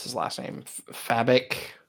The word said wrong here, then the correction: Fabok: Oh, Fabok. – Fabok, yeah.